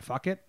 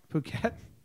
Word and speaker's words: Phuket: Fuck it. How do you say it? Thailand? – Phuket. – It's not fuck it.